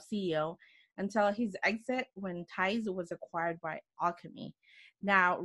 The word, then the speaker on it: TiZE: Did I say it correctly? TiZE. TiZE. That's correct.